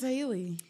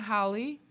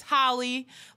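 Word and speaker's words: Halle: It's spelled like Holly Berry. – Oh, it is spelled like Holly Berry. – Yeah, so it's Chloe and Holly. I've been saying Chloe and Haley for so long, but she emphasized. She's like, hi, it's Holly.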